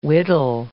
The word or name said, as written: whittle